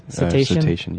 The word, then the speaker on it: Cetacean: Cetacean, yeah.